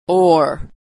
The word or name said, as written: ore